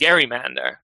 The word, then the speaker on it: gerrymander: It was also the case that it was originally pronounced gerrymander, as you might suggest from this portmanteau of the last name of Governor Gerry and the salamander that this district was said to resemble.